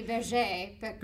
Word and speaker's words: Berger Picard: And it, I think I pronounced it the Berger Picard, but it's the Berger Picard.